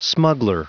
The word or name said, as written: smuggler